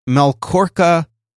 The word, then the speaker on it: Melkorka: So perhaps the New York Post reporter Melkorka Lisia, I hope I'm saying that right. M-E-L-K-O-R-K-A. Melkorka, L-I-C-E-A.